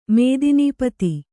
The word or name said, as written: ಮೇದಿನೀಪತಿ